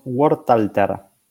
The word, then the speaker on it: Wortalter: Nosotros lo pronunciamos en castellano, por lo cual decimos Wortalter. – Wortalter.